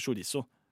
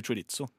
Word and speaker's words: chorizo: twist ikke, du sier ikke chorizo. Nei. Mange sier chorizo, men det er helt feil. Ja. Vet du hvordan det egentlig uttales? Hvordan? Chorizo.